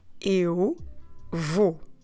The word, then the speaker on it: vou: V-O-U. So every time we have this diptong, this O, U together, what you need to do is the sound O.